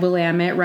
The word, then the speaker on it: Willamette: And it actually says in here that Willamette rhymes with damn it, as locals are fond of saying.